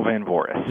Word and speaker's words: van Voorhis: It's Daniel Van Voorhis.